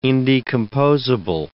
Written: indecomposable

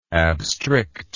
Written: abstrict

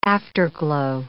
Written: afterglow

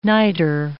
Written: niter

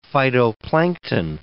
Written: phytoplankton

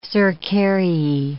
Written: cercariae

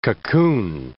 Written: cocoon

